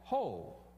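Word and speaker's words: ho (הוֹי): it's pronounced ho. If you have a King James Bible, isn't that the first word? Ho, all ye who thirst, you know. Just says, ho.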